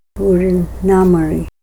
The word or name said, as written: bur'a na mori